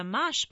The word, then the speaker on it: mâche: Mâche, pronounced mâche, is an heirloom green prized for centuries by Europeans for its fresh, nutty flavor.